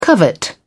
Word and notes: covet: British English

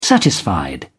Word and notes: satisfied: British English

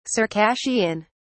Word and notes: circassian: British English

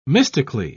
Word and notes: mystically: British English

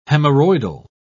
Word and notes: hemorrhoidal: British English

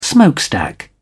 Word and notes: smokestack: British English

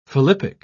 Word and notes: philippic: British English

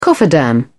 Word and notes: cofferdam: British English

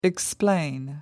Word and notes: explain: American English

